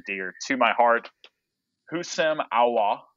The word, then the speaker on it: Aouar: Apparently you like basically don't pronounce the one consonant that's in his last name, just Aouar, and that's it.